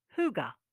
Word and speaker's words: Hygge: Hygge, pronounced Hygge.